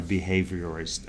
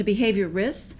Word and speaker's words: behaviorist: Because everyone wants to put an AL in there. – Yeah. – So it's a behaviorist, like dermatologist. We just don't have the gist.